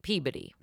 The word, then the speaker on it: Peabody: We pronounce it Peabody. – So like the rest of the normal educated America is Peabody? – Yeah, but welcome to Massachusetts.